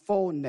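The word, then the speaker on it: phone (φωνή): In the Greek, the word voice is the word pronounced phone, or the word we get is phone.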